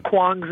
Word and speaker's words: Kwang: And the way you pronounce Kwong's name is Kwong. – Kwang? – You pronounce Kwong. – Kwang? Is it Kwang? – Kwong. – Kwang? – Kwong. – It's spelled Kwang. K-W-A-N-G. Is that Kwong? – Mm-hmm.